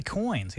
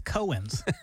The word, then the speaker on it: coins: And he also can't say coins. He says Cohen's.